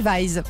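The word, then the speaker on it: Wees: Et je vois juste à côté d'ailleurs du nom de Zoé Wes prononcé Zoé Weiss.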